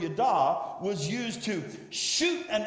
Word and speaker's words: yadah: The first one is yada. Everybody say yada. You spell that Y-A-D-A-H. And now it's not yada. You know, we say yada, yada, yada, yada. That means something's not important. It's yada.